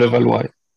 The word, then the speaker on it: Levallois: it's Paris Levallois. Is that how it's pronounced? – Yes. Yeah. So, Levallois.